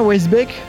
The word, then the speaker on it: Weissbeck: Gaëtan, je dis bien Weisbeck, ça va la prononciation Ouais, y a pas de souci.